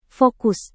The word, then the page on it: fokus: indonesian